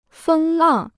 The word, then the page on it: 风浪: fēng làng